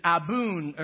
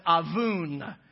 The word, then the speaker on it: abun: In the English language, our two English words are one Aramaic word, which is abun, or some pronounce it avun.